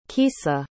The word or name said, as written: keesa